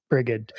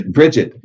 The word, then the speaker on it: Brigid: Brigid. Am I saying it wrong? – Brigid. Yeah. Yep.